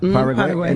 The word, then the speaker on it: Paraguay: Paraguay? – Paraguay.